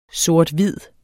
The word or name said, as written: sort-hvid